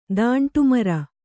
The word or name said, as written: ದಾಂಟುಮರ